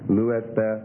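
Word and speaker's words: lu'espa (λύεσθε): Number four, second person plural present middle and passive imperative would be lu'espa.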